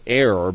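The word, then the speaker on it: Er: some pronounce it error, because it was an error what he has done.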